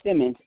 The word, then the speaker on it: Simmons: Janetha, T as in Thomas, T-H-A. – T-H-A. So that's J-E-N-E-T-H-A. – Correct. – And what's your last name? – Simmons, S-I-M-M-O-N-S.